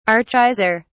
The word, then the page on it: archaizer: British English